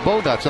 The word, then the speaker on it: Bogaerts: So I said, "Sure," and I said, "Xander Bogaerts," and they all start laughing. So, I mean, am I saying it wrong? – I think you say it fine.